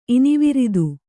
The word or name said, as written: ಇನಿವಿರಿದು